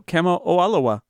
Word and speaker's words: Kamo'oalawea: Yeah, so Kamo'oalawea. – Kamo'oalawea. – You pronounce all the vowels. That's what you have to do.